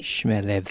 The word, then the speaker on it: Chmielewski: You've sort of half said it right. It's Chmielewski. So if you think Schmel, like smell but with sh, Schmel, and then ev, and then ski. So Chmielewski. – Chmielewski.